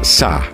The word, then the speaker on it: SZA: Esta rapera estadounidense llamada Sa, se escribe S-Z-A, irrumpe en todas las listas con el tema central de la película de artes marciales Kill Bill 2.